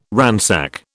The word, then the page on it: ransack: British English